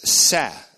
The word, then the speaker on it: seh: The Hebrew, however, is seh, simply S-E-H in transliteration, and that means specifically lamb.